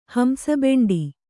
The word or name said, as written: ಹಂಸಬೆಂಡಿ